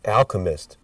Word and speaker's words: Alchemist: Alchemist? A-L. It's chemist with A-L in the front. – Alchemist.